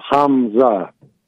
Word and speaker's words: Hamza: No, Hamza. – With an M? – Yeah.